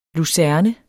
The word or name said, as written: lucerne